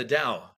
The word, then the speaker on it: Tao: the Tao, is that correct? – Yeah, the Tao. The Tao. It's spelled T-A-O, but it's pronounced. Yeah, Tao, with the D, okay.